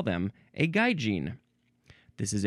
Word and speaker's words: gaijin: Next is gaijin, or could also just be gaijin. So gaijin, that's the first pronunciation, so that's what I'll say. G-A-I-J-I-N, gaijin, noun from 1964.